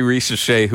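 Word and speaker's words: Risacher: Both, I'm going to mispronounce his name, Rashear going to the Mavericks. – So Zachary Risache, who was Risache, the number one pick in one of the worst drafts of all time.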